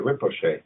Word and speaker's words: Rinpoche: Is uh, the Rinpoche? Is that how you pronounce it? Oh, that's pretty darn good. The Rinpoche, yes.